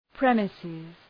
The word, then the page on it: premises: ‘preməsız